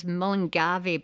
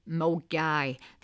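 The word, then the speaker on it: Milngavie: Now, some people, if not from Glasgow, they pronounce it as Mullingavy, but Glaswegians, they know it as Mulgaigh.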